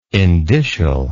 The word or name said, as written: indicial